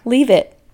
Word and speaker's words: Leavitt: Leave it. – Love it. – Yeah, it's spelled leave it.